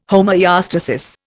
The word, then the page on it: homoeostasis: Indian